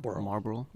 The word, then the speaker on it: Marlboro: Marlboro? – Marlboro, yeah.